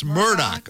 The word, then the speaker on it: Murdaugh: It's pronounced Murdoch. O.J. knows how to pronounce it.